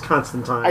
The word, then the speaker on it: Constantine: So apparently Dan was right, and it's pronounced Constantine.